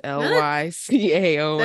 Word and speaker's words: Lycaon: I'm probably saying it wrong. It's Lycaon. – That sounds right to me.